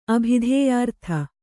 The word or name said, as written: ಅಭಿಧೇಯಾರ್ಥ